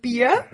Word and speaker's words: Beer: Und Beer wird genauso geschrieben, wie es ausgesprochen wird.